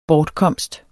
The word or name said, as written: bortkomst